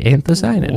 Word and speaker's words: anthocyanin: Anthocyanin.